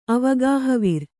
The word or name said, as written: ಅವಗಾಹವಿರ್